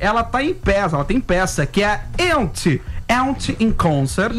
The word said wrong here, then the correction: Yentl: Yentl. – Eu vou fazer com pronúncia Yentl in Concert.